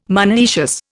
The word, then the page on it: monoecious: Indian